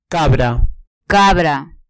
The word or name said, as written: cabra